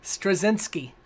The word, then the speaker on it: Straczynski: Uh, oh, it always makes me laugh when people can't properly pronounce JMS's name, especially on YouTube. Straczynski. J. Michael Straczynski.